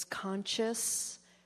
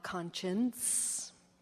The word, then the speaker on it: conscious: And one particular time it was, honey, it's conscious, not conscience.